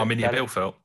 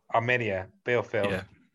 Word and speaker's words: Arminia Bielefeld: Was it Arminia Bielefeld? – Arminia Bielefeld, minus one as well.